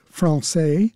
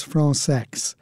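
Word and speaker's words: Françaix: He spells it F-R-A-N-C-A-I-X. It is commonly pronounced Francais, but the composer himself and his close circle of friends pronounced it Francex. He said that he was tired of correcting people, so if they want to pronounce it Francais, that's fine with him, but he prefers Francex.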